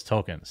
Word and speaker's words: Tolkien: Tyler Trent, Tolkien equals token. Sorry, but it's been bugging me. All right, I'll say it right.